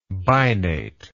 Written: binate

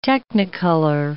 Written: Technicolor

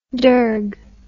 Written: dirgue

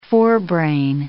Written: forebrain

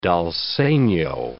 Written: dal segno

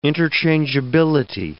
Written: interchangeability

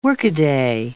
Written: workaday

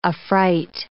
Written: affright

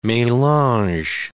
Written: melange

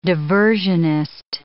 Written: diversionist

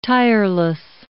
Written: tireless

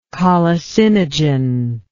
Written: colicinogen

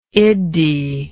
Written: iddhi

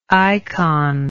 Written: ikon

